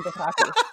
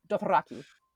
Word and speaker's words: Dothraki: You can't not roll your R's with Dothraki. Dothraki.